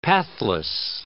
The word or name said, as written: pathless